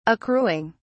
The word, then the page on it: accruing: British English